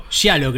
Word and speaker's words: Giallo: Giallo creo que es. – Giallo. Italiano.